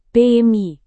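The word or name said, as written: pn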